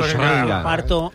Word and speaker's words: Schrödinger: ¿Cómo se pronuncia Schrödinger? Correctamente. – Schrödinger.